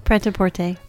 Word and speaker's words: Prêt-à-Porter: and Stiggs, Fool for Love, Vincent and Theo, The Player, Shortcuts, Prêt-à-Porter. Is that how you pronounce that? – Uh-huh. Prêt-à-Porter.